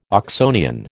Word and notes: Oxonian: American English

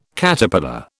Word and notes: Caterpillar: British English

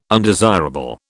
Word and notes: undesirable: British English

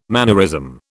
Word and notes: mannerism: British English

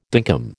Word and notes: dinkum: American English

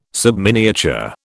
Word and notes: subminiature: British English

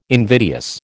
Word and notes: invidious: American English

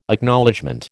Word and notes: Acknowledgement: American English

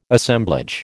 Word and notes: Assemblage: American English

